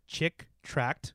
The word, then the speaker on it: Chick Tract: Yeah, so Dark Dungeons is a literal adaptation of a type of comic called correct me if I'm saying this wrong, Chick Tract. – Yes. – Correct.